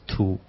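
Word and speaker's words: 荼: And actually, the word for tea in ancient times was another character altogether, pronounced tu.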